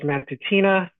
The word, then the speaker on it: Matutina: My name is Patrick Matutina.